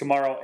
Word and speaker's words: Kamara: Is it Kamara? – Kamara, yeah.